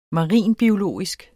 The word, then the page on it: marinbiologisk: mɑˈʁiˀn-; maˈʁiˀn-